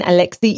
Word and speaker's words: Alexi: I'm Hélène, Hélène Alexi Ioannides.